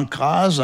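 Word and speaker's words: Cras: Et ce nom se prononce crase, avec un Z, SZ, nous précise Michel Fleury, musicologue.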